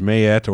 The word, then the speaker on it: Maat: The goddess Maat, pronounced Maat or Maat, Right.